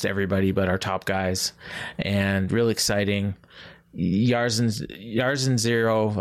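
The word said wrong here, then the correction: Jairzinho: I can never pronounce it. Jarzinho.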